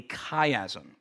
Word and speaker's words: chiasm: Now, the fancy $10 name for, it for this is called a chiasm. I'll spell that C H I A S M, chiasm.